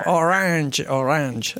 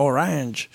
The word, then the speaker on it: Orange: Orange. Ricordiamo sempre, ti ricordi la pronuncia Orange? Non orange.